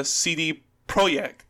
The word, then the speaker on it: CD Projekt: But there was something that came out where it were interviews with more than 20 current and former CD Projekt, that's how you pronounce it, Projekt Red Staff, and they were talking about their experiences on working on the game.